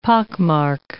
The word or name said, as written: pockmark